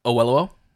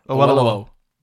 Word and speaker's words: Oyelowo: So I watched Waterman, which is directed by David Oyelowo. – Can you say that again? – David Oyelowo. – Yes, please. I can't pronounce it. – Oyelowo. – Yeah, there you go.